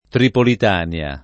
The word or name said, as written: Tripolitania